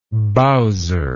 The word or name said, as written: bowser